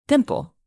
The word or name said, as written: dimple